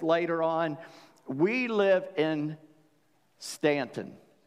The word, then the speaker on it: Staunton: Now, if you look for that on a map or Google it, you won't find it, because it's spelled S-T-A-U-N-T-O-N. It spells Staunton, but I say Ant, so this is real easy for me. Anton is the end of Stanton, right?